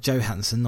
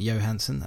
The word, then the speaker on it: Johansson: People are very anti Scarlett Johansson, as she pronounces it. I saw her pronounce that the other day. – All right. – Johansson, not Johansson, as I would say.